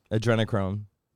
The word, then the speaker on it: adrenochrome: Adrenochrome. – I don't know about the adrenochrome. – That is real. – Oh, you pronounced it correctly, so apparently.